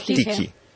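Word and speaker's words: Tychy: Tije. – Bueno, es que sí, se escribe Tichi, se pronuncia Tije.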